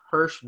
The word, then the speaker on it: Harsh: My name is spelled H-A-R-S-H, Hirsch. But people think it's Hirsch, but no, it's pronounced Hirsch Gosar.